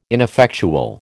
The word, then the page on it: ineffectual: American English